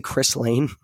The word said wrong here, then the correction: Ghislaine: Isn't that her name? Chris Lane? Chris Lane? – No, yeah. Ghislaine. – Well, it said on the documentary, it said Chris. Whatever. – No, it's Ghislaine.